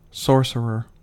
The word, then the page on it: sorcerer: American English